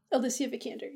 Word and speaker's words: Alicia Vikander: She's Alyssa Vikander. Is that how you pronounce it right? – Alicia Vikander, yeah.